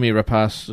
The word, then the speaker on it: Noomi Rapace: Noomi Rapace, is that pronouncing her name correctly? – I think so.